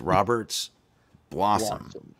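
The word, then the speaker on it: Roberts Blossom: We're not mispronouncing it. That's the way it's pronounced, is Robert's Blossom.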